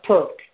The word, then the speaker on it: Turk: Turk, T-U-R-K.